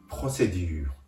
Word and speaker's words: procédure: フランス語で手続きは p r o c d u r e もう一度発音しますフランス語で手続きは procédure